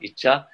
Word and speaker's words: Icha: I should pronounce it right. It's a long A at the end. Icha.